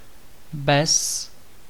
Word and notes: bez: [bɛz]

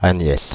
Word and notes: Agnès: [a.ɲɛs]; /a.ɲɛs/

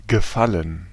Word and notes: gefallen: /ɡəˈfaln̩/; /ɡə.ˈfa.lən/

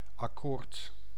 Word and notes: accommodement: France; [a.kɔ.mɔd.mɑ̃]

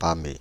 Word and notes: pâmer: /pɑ.me/

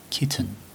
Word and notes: kitten: American English

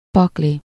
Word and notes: pakli: /ˈpɒkli/

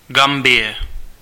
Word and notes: Gambia: [ˈɡæm.bi.ə]; American English

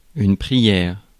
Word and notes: prière: France; [pʁi.jɛʁ]

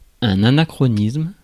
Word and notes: anachronisme: /a.na.kʁɔ.nism/

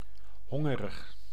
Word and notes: hungry: /ˈhʌŋ.ɡɹi/

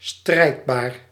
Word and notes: agressif: /a.ɡʁɛ.sif/; France

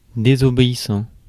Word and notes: désobéissant: [de.zɔ.be.i.sɑ̃]

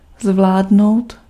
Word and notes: dominer: France; /dɔ.mi.ne/

